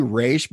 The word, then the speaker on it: Ra's: Well, I'm saying Ross because that's how they say it in the movie. – I say Raish because that's how Dan Didio and Den- Denny O'Neil, the guys who created the character.